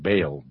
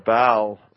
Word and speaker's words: Baal: Some pronounce that Baal, but when I think of Baal, I think of bailout. You know, so. – Well, I call it Baal.